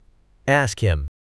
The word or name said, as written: ask him